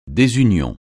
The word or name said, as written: désunion